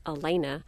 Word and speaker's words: Alana: My family calls me, it's written A-L-A-N-A. My family pronounces it Elena, but out in the world, that name often turns into Alana.